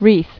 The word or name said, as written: Wreaths